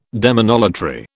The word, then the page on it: demonolatry: British English